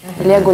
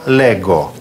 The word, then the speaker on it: leggo: Здесь особенность в том, что в некоторых лицах произносится «г», а в некоторых «дже». – «Легу», «ледже», «ледже». – Да, еще раз. «Читать», «леджере». «Я читаю», «io leggo».